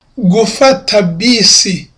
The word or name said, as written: gufata bisi